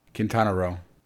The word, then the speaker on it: Quintana Roo: Quintana Roo.